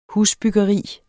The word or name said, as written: husbyggeri